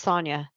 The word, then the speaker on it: Sonia: There's a little bit of O, long O in there, but it's Sonia. – Sonia. – You got it. Rhymes with lasagna.